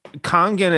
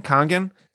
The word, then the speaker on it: Kangen: It's Kangen. I think it's spelled K-A-N-G-A-N, Kangen, and it's Japanese,